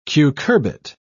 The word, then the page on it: cucurbit: British English